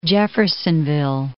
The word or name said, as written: Jeffersonville